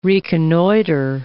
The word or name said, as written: reconnoiter